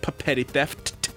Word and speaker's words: Papetti Theft: Yes, that is how it's pronounced. Two P's at the beginning, two T's at the end. Papetti Theft.